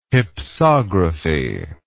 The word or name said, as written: hypsography